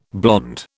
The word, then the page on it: blond: British English